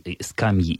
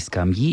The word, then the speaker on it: скамья: Что во всех формах возможно оба ударения «скамьи» и «скамьи», и «о скамьях», и «о скамьях».